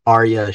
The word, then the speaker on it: Arya: Hey everyone, my name is Arya Shahi. So that's how you say it. Arya, like the Italian Arya, and Shahi, like Shahi, I guess.